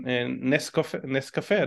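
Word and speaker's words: Nescafé: So I just poured myself some Nescafe. I don't know how you pronounce it correctly in English. – No, that's perfect. Yes.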